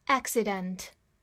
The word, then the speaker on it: accident: Accident，A C C I D E N T，accident，字母 A 发大口的 a，中间两个 c，第一个发 k，第二个发 s，字母 I 还是发它最常见的发音 i，acci。然后后面 d e n t the end dent accident accident，名词，表示事故或者意外。